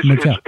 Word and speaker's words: Majel: Not Majel. – I'm sorry. It is Majel.